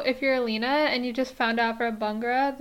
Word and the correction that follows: Baghra: that Baghra. Baghra. – Baghra. I literally keep on pronouncing her name as Bangra.